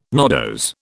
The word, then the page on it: nodose: British English